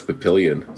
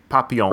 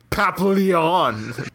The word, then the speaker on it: Papillon: I always thought it was Papillion, so that was my takeaway from today. I learned how to pronounce that movie correctly. – Yeah, it's French. Papillon.